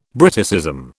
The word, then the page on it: Briticism: British English